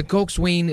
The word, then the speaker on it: coxswain: In modern rowing, the coxswain is the strategist, navigator, motivator, and field commander of the crew, mga kapuwersa. Ang pagbasa po, ng cox ay coxswain, pero pag pronounce po nito ay coxswain.